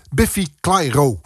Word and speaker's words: Biffy Clyro: Peter zegt, Biffy Clyro is Schots. En dat spreek je niet zo uit. Zo klinken Schotten niet. Ik zal het ook niet meer doen. Goed, Biffy Clyro. Doe ik het zo.